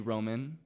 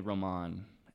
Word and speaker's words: Roman: My parents call me Roman. But a lot of people call me Roman, and I don't mind it.